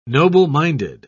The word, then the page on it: noble-minded: British English